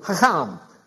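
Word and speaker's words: chacham (חכם): And the Hebrew word is chacham. You got to kind of say it with phlegm in your throat.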